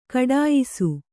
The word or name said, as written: ಘಟಿಯಿಸು